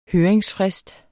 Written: høringsfrist

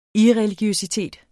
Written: irreligiøsitet

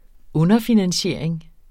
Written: underfinansiering

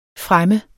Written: fremme

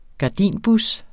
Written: gardinbus

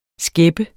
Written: skæppe

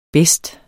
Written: bedste

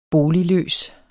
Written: boligløs

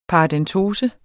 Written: paradentose

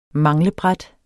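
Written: manglebræt

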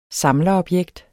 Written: samlerobjekt

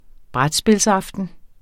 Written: brætspilsaften

